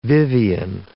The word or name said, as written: Vivien